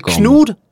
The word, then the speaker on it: Knud: Eller Knud Reimer. – Så kan du vara. Fantastiskt danskt uttal.